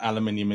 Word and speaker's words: aluminium: Forever saying that aluminium is the right way to say it, even though I think it is, because apparently it's us Brits that are wrong on that one.